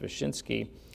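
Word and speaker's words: Wyszyński: And it's what happened to the primate of Poland, Cardinal, I believe it's pronounced Wyszynski,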